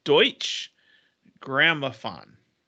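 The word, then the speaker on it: Deutsche Grammophon: It's Deutsch Grammophon.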